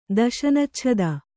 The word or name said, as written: ದಶನಚ್ಛದ